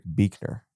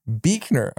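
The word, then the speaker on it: Buechner: Okay, so Frederick Buchner, or if we're gonna quibble over the pronunciation of Avoda or Avoda, I've been told that it's Frederick Buchner. – Buchner, okay.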